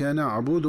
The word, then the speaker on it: نعبد: وكذلك عند قراءة الآية التي تليها وهي قوله تعالى إياك نعبد وإياك نستعين، فينبغي التنبه للنطق الصحيح لكلمة نعبد عند الوصل، فتقرأ بضم الدال ضمة خالصة هكذا إياك نعبد وإياك نستعين. ومن الخطأ قراءتها في الوصل بتسكين الدال هكذا إياك نعبد وإياك نستعين.